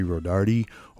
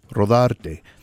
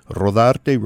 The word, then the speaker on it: Rodarte: Now, I know that many know me in the community as Larry Rodarte or Larry Rodarte, you know, with the connection to Rodarte Builders. But the actual way you pronounce my name is Larry Rodarte. Rodarte, rolling those R's.